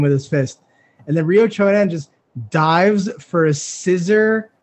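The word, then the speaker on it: Ryo Chonan: For the people that don't know, like one of Anderson Silva's biggest losses was against Ryo Chonen. Did I say his name correct? – Yep.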